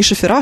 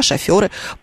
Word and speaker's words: шоферы: Они вместе не шофера, а шоферы.